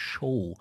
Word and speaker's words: Xow: die nennt sich Show. Das wird mit XOW, das ist wohl wahrscheinlich, weil das dieses X wird da im Maltesischen wohl mit SCH ausgesprochen.